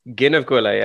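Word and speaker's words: Gneeveguilla: Is that how you pronounce it? – Close enough. It's a hard one to pronounce. It's Ginnaguilla. – Ginnaguilla, yeah.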